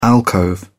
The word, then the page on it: alcove: British English